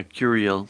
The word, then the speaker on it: Curiel: Well, it turns out that Barack appointed this lawyer, this judge, Gonzalo, how do you say his name? Gonzalo Curiel? – Yeah, Curiel. Yeah. – All right. – If you're going to say it in English, you know.